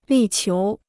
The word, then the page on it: 力求: lì qiú